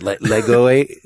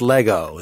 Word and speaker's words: Lego: It's Lego with an E at the end. Lego-y, but it's still pronounced Lego.